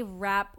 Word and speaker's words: Rapp: But yeah, the only reason I know Renee Rapp's, that's how you say it, right? – Singular rap. – Rap? Okay. Renee Rapp.